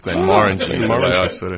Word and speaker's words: Glenmorangie: Glenmorangie is the right way to say it. – Like Moran, like Dylan Moran, the comedian.